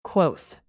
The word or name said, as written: quoth